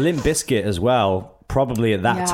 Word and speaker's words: Limp Bizkit: Because people were saying Limp Bizkit, and I remember that really annoying me. – You gotta pronounce the Z, right? – Yeah, you have to pronounce it. So I'm like toddling around backstage being like, it's Limp Bizkit.